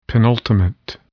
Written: penultimate

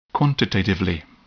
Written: quantitatively

